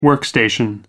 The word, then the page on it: workstation: American English